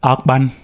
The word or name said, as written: Akpan